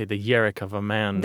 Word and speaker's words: yarak: Alas, poor Yerrick? – No, this is spelled Y-A-R-A-K. Yerrick.